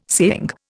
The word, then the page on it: saying: Indian